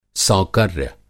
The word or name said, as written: सौकर्य